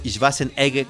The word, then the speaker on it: Schwarzenegger: Arnold Schwarzenegger o Arnold Schwarzenegger, como se supone que se pronuncia,